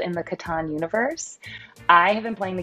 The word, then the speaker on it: Catan: And yes, I said Catan, not Catan. Turns out the Catan heads have been saying it wrong it, as I understand it, intends to be Catan. It's a German game, so if you kind of think about that, pronunciation can also help.